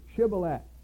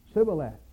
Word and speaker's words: Shibboleth: So Jephthah's men would cry out across the river, "Say Shibboleth." And the enemy soldier would say Sibboleth, because he couldn't pronounce it.